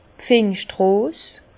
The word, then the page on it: d' Pfingstrose: Bas Rhin; Herrlisheim